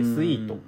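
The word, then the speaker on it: 組曲: 曲」と書く漢字で書いて「スイート」と読むん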